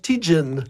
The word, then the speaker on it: Tête Jaune: C'est Tête Jaune et Tête Jaune Cash, mais eux, à l'anglaise, ils le prononcent Tijin.